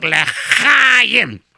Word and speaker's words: L'chaim: You say, not che. – L'chaim. – Nein, zug L'chaim. – L'chaim. – Can't pronounce this.